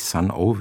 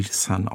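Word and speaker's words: Sunn O))): Geschrieben ja Sun mit Doppel-N und dann O und mit 3 Schallwellen dahinter, also nicht Sun-O, wird auch wie Sun ausgesprochen.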